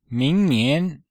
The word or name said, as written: ming2nian2